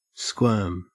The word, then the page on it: squirm: Australian English